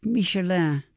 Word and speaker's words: Michelin: Se pronuncia a la francesa, Michelin, porque quien las otorga es la guía Michelin, una especie de libro gaceta con recomendaciones de rutas de viaje y alta crítica culinaria patrocinada por la empresa homónima de neumáticos.